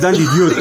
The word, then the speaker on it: DiDio: porque no es Dan Didio. – Es Dan Didio. – Exactamente. Es la pronunciación de Didio. Es como Art Tibet. Es Dan Didio. – Didio. – Así se pronuncia.